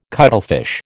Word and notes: cuttlefish: American English

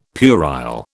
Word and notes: puerile: British English

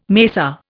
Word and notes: mesa: Indian

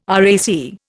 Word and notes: RAC: Indian

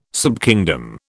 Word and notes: subkingdom: British English

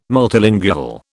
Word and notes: multilingual: British English

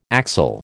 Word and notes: axle: American English

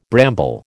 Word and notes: bramble: American English

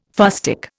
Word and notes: fustic: Indian

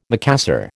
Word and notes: Macassar: American English

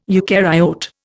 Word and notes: eucaryote: Indian

